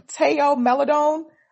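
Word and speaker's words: Théo Maledon: Teo Maledon.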